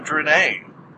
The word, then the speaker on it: Drennai: I only ask one thing, and that is that you stop these people from invading the Drennai." Not the Drennai. I think I've been saying the name wrong the whole time. – No, you in fact have been saying it correctly the whole time.